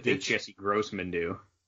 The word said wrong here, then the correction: Crossman: Oh, Jesse Grossman? – Crossman. I'm sorry, I said her name wrong.